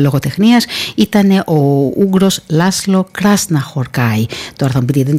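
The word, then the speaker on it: Krasznahorkai: Γιατί οι Ουγγαρέζοι, οι Ούγγροι, οι Μαγιάροι, όπως θέλετε πείτε τους, και οι Φιλανδοί δεν τονίζουν πουθενά αλλού τις λέξεις παρά μόνο στην πρώτη συλλαβή του κάθε ονόματος. Ναι, είναι, πώς τον λέγαμε εκείνο τον εξαιρετικό, ήταν ας πούμε ο Κος Κενιέμι, ήταν κάποια άλλα ονόματα, ένας εξαιρετικός οδηγός ράλι. Ναι, όλα είναι στην πρώτη συλλαβή του ονόματός τους. Εκεί τονίζονται, άρα λοιπόν Κράσνα Χορκάι.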